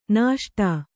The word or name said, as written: ನಿಸುಟು